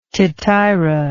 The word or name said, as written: tityra